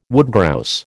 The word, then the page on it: woodgrouse: American English